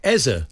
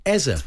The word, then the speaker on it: Eze: And Eze does say Eze, so he is technically Eze.